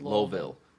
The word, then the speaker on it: Louisville: So it's Louisville. And that's how you pronounce it.